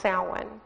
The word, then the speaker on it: Samhain: The actual term for it that they use is, it's spelled Samhain, but it's not actually pronounced that way. It's pronounced Samhain.